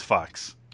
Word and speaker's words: Fuchs: Oh, Jason Fuchs? – It's Fox.